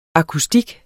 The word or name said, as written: akustik